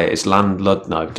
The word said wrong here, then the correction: Llandudno: That's not how you say it. It's like Llandudno, I think you say it.